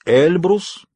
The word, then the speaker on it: Эльбрус: По старым делам он был знаком с разными людьми, в том числе с неким кавказским бизнесменом по имени, извиняюсь, Эльбрус, ударение на первом слоге, Казбекович.